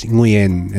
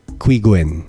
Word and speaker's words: Qui Nguyen: I thought it was Nguyen. And then I Googled how to pronounce his name and I looked for a video clip of him himself. – Okay. – He pronounced it Qui Gwen.